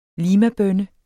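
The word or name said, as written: limabønne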